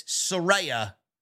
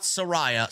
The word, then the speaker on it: Saraya: The other big thing that happened in AEW this week is that Saraya, she actually came out and said, you guys have been saying my name wrong. It's Saraya, not Saraya.